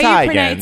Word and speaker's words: Teigen: Okay, so it's spelled T-E-I, right? – T-E-I-G-E-N. – Yeah, that's a German name. And anytime you have an E-I, you pronounce it I.